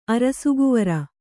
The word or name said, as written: ಅರಸುಕುವರಿ